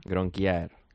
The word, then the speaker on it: Gronkjær: Gronkjær.